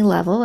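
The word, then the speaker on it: Leavell: So Made to Bloom is available on all of the streaming services under my name, Stephanie Level. And the way you spell that is the word leave, L-E-A-V-E, with two L's at the end.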